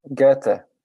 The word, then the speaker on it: Goethe: Goethe. It's how you say it. – Okay. Goethe. I heard, but when you say it sounds like goatee.